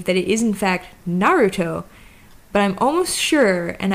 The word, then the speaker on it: Naruto: Anyway, so we'll talk about the word Naruto has three mora. Now, there's another rule in Tokyo Japanese that says that the first mora of the word is always low, unless the word's accent is on the first mora, which I'm positing is the case for Naruto.